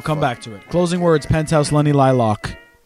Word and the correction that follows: Lilac: You still said it wrong. – Shut up. – Is it Lilac? – No. No, it's not Lilac. – Oh, love you a long time. – You know what the flower is? – A lilac? – Yeah, a lilac. – A lilac. – There you go. That's how you say it.